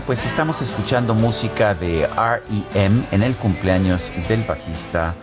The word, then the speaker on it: REM: REM, me preguntan por qué lo pronuncio así. Bueno, así se pronuncia en inglés. Eh, hay mucha gente en México que lo pronuncia REM porque son pues así son las ah, iniciales de la... Ah, eh, así se pronunciaría en español, españolizadas estas iniciales, REM.